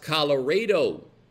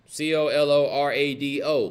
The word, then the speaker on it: Colorado: It is pronounced Colorado, Texas. C O L O R A D O, Colorado.